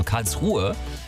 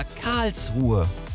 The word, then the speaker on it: Karlsruhe: Ähnlich geht's SWR3-Hörer Roland, der schreibt, ja, hallo, es klingt immer wieder schrecklich, wenn bei der Stadt Karlsruhe die zweite Silbe betont wird. Also Karlsruhe, es heißt aber Karlsruhe.